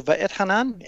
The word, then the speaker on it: Va'etchanan: Okay, so Va'etchanan, and by the way, the pronunciation was very good, Rob.